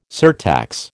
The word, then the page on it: surtax: American English